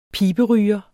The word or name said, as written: piberyger